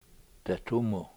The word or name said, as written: detumu